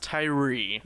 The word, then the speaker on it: Thuirer: So it's pronounced Tyree, yeah. – But it's spelled T-H-U-I-R-E-R.